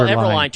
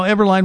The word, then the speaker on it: Everlayn: It's E-V-E-R-L-A-Y-N, and now you understand why people pronounce the name the way that they do. E-V-E-R-L-A-Y-N. Borges, B-O-R-G-E-S, rhymes with gorgeous.